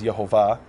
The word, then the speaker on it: Yehovah: Now, the Hebrew name in Hebrew is Yod He Vav which some pronounce as Yahweh or Yahweh. I pronounce it as Yehovah for various uh, textual reasons.